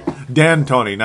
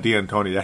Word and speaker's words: D'Antoni: D'Antoni, not D'Antoni.